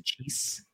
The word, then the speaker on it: Saggese: Is it pronounced Sejace? Thomas Sejace? Am I getting that right? – It should be, if he's properly Italian. I'm pretty sure they say Sejace. Sejace. Yes, that's how you would say it if you were actually a good Italian.